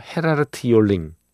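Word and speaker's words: Gerard Joling: 네덜란드에서 이 네덜란드 발음으로는 헤라르트 요링이더라고요. 헤라르트 요링.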